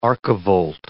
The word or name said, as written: archivolt